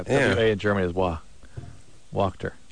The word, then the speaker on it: Wachter: It's phonetically, it's W-O-K. – T-E-R, so Walkter.